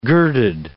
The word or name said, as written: girded